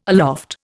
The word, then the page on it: aloft: Indian